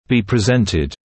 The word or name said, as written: be presented